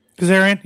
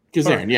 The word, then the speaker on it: Kazarian: Santana will be Kazarian, right? Am I saying that right? – Kazarian, yeah. – Kazarian, yeah.